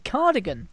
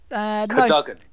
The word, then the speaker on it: Cadogan: The commentator kept on saying Cardigan for Sutton United. He's referring to Kieran Cadogan. It's spelt with an O rather than an I.